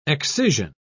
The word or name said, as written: excision